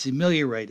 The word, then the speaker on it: ameliorate: I wanted to impress upon my professor, so I said the word ameliorate. And he says, remember his name was Dr. Graff. He says, you're pronouncing it wrong. And guess what, folks? He was right. It's ameliorate. It means to improve.